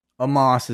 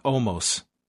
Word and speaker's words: Omos: AJ Styles' bodyguard, we've been pronouncing his name wrong, and the announcers let us know it is Amos. – Amos is how you pronounce it, yeah. – Not Omos.